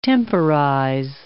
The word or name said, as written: temporize